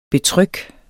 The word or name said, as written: betryk